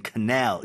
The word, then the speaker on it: canal: 캐널이 나왔네요. 캐널. 커넬입니다. 여러분들. canal 이라고 읽어주셔야 되고요.